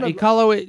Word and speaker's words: Iqaluit: Yeah, Iqaluit.